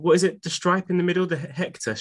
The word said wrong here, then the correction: Hechter: stripe, did you say? – See, funnily enough, I actually Googled how to pronounce it because I thought if I'm going to come on here and be a smart ass, I better have all my facts and pronunciations right. So it's the Hechter stripe.